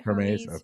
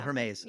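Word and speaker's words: Hermès: It's actually, I think it's Hermes. Is that how they say it? – Hermes. – Oh, yeah. Hermes.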